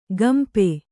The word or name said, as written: ಗಂಪೆ